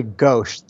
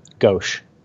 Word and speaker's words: Ghose: It's actually Ghosh. The H at the, there's no H at the end, but there should be. Ghosh.